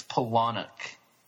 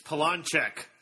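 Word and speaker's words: Palahniuk: By the way, guys, if you don't know how to say it, I know how to say it. It's Polonic. – It's Polonchek. Now, I knew a kid growing up named Chris Polonchek.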